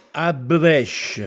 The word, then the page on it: Abbuvèsce: Castellanetano